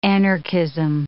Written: anarchism

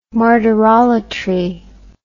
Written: martyrolatry

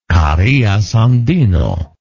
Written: Carias Andino

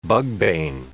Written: bugbane

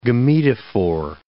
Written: gametophore

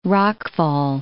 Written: rockfall

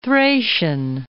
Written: Thracian

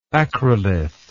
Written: acrolithic

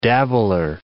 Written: dabbler

